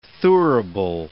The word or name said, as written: thurible